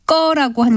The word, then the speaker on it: 거: 이때는 된소리로 나는 꺼가 아니라 예사소리인 거라고 쓰고 발음은 꺼라고 하는 것이 맞습니다.